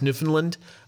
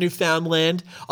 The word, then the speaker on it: Newfoundland: I also, for our Canadian listeners, I just want you to appreciate how I pronounce Newfoundland. It's not Newfoundland.